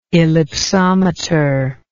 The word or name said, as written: ellipsometry